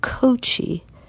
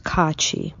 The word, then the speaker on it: Kachi: Hello, my name is Teresa Kachi. Many people at first pronounce my last name with a long O and say Kochi, but it's really pronounced Kachi.